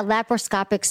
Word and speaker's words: laparoscopic: It was discussed that it would try the, I think I'm pronouncing it right because I was on the speakerphone, laparoscopic first. – Yes. – To just remove the fibroids. Did I get that right? – Yeah, laparoscopic surgery.